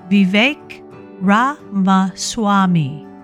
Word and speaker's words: Vivek Ramaswamy: Other pronunciations making the U.S. list include the name of biotech entrepreneur and Republican presidential candidate Vivek Ramaswamy, pronounced Vivek Ramaswamy, and the late Karl Lagerfeld's cat, Choupette, pronounced Choupette, who made headlines as the Met Gala honored the late Chanel designer.